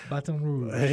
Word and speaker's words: Baton Rouge: Baton Rouge.